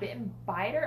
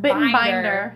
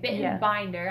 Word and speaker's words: Bittenbinder: Is it Bittenbiter or Bittenbinder? – Binder. Okay, Bittenbinder. Yeah. I've been saying it wrong this like whole time.